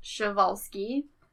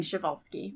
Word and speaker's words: Przewalski: All right, so, and a few ways you can pronounce it is Przewalski, Przewalski, or Przewalski. So we'll just be saying Przewalski. – Yeah. – Because that was, like, the most common pronunciations that I could find of it.